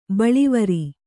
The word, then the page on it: ಬಳ್ಳಿವರಿ: baḷḷivari